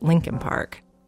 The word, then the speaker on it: Leakin Park: It's actually spelled Leakin Park, L-E-A-K-I-N, but almost everyone in Baltimore pronounces it Lincoln Park.